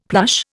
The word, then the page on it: plush: Indian